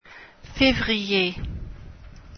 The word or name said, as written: février